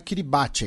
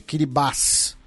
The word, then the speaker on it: Kiribati: Inclusive, várias pessoas lembrando né, que a pronúncia não é Kiribati, é Kiribás.